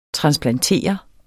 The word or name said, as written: transplantere